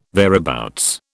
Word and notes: thereabouts: British English

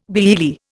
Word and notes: biyearly: Indian

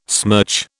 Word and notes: smirch: British English

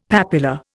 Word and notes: papula: Indian

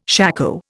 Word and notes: shako: Indian

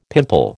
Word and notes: pimple: American English

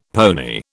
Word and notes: pony: British English

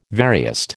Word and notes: veriest: American English

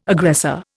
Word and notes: aggressor: Indian

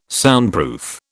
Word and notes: Soundproof: British English

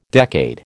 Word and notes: decade: American English

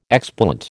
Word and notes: explant: American English

